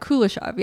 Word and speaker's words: Kuleshov: Kuleshov, yeah.